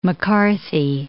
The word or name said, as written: McCarthy